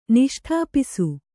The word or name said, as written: ನಿಷ್ಠಾಪಿಸು